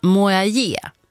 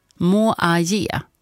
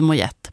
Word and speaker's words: Moyet: Tack så mycket Stina då för att du skrev in att uttalet ska vara som i Allison Mojaje. Mojaje. Ja, Mojaje. Inte Mojet.